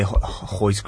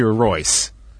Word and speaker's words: Royce: Like, how do you say Hoist Gracie? What's the proper way of saying Hoist? You know? – I've heard it's like, it should be Royce. – Yeah. – Like, it's the Gracies that have come up with the silent R gimmick. – There's no such name. There's no such thing as Hodger. Okay, you see R-O-G-E-R.